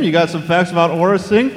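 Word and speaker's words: Aurra Sing: The official pronunciation for our next character is Aura Singh.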